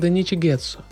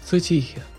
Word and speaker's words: Цутихи: Некоторые подумают, что мое имя произносится как Даничи Гетсу, но все-таки оно звучит Цутихи.